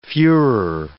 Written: fuhrer

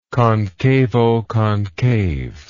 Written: concavoconcave

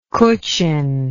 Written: Kutchin

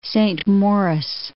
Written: Saint Maurice

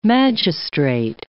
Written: magistrate

